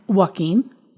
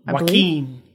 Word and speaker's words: Joaquin: Joaquin? – Joaquin. – Joaquin.